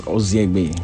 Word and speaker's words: Ozienegbe: The N in Ozienegbe, the N is silent, so it's Ozienegbe.